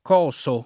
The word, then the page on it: cosare: lingua italiana